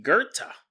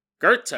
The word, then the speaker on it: Goethe: Jonathan said his name is actually Goethe. Goethe. I was mispronouncing it, and he wasn't just sending me an email to correct me.